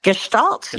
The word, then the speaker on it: Gestalt: is it Gestalt? Is that how I say it? – Gestalt.